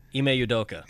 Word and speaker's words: Udoka: Ime Udoka.